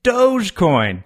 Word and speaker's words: Dogecoin: According to the cryptocurrency's co-founder, Billy Marcus, the correct pronunciation is Dogecoin, D-O-H-J coin. – Although, some prefer to pronounce the first syllable like dog or doggy.